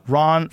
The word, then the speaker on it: Rahn: In 1983, Chris Dunn interviewed Dr. Ronald Ron from the, is that, am I saying his name right? – R-A-H-N. – Ron from the Ron Granite Surface Plate Company in Dayton, Ohio.